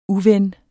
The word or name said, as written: uven